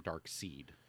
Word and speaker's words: Darkseid: But it's spelled that way, and it, well, because then they would, there's a whole thing where we always thought it was Darkseid, and then DC confirmed Darkseid.